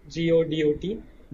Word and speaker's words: Godot: Godot, the most popular reason people go with Godot is because, or people pronounce it differently, it's Godot, is because it's open source.